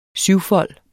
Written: syvfold

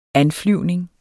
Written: anflyvning

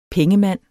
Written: PENGEMANDS

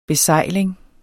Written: besejling

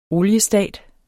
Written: oliestat

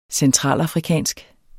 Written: centralafrikansk

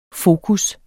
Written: fokus

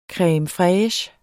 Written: creme

